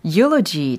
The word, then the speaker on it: eulogy: eulogy 철자를 알려드리면 e u l o g y라는 철자이고 이게 발음이 바로 eulogy 이렇게 하는 거죠.